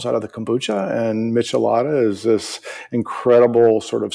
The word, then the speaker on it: Michelada: One other thing that I came across when I was checking out your work before deciding to do a show, Michelada, how do you say it? – Michelada. It's a Michelada.